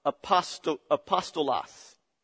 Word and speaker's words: apostolos: That word apostle in the Greek is pronounced apostolos.